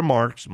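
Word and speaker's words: Marx: The smile on her face, if people go to my Instagram, Victor Marks, my name is spelled with an X, look at the before and after of this girl.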